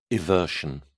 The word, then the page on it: eversion: British English